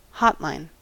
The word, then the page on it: hotline: American English